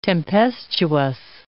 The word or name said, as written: tempestuous